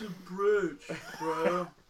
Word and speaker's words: brooch: Brooch. It's a brooch, bro.